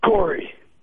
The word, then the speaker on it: Corey: C-O-R-E. – So it looks like core, but it's Corey.